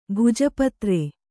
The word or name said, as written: ಭೋಜಪತ್ರ